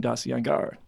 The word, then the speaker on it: Darcy Ungaro: I'd like to welcome Darcy Ungaro.